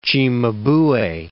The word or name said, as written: Cimabue